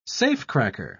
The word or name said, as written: safecracker